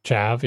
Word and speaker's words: chav: I think I'm pronouncing that right. – CHAV, yeah, C-H-A-V.